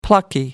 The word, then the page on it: plucky: American English